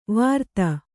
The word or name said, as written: ವಾರ್ತ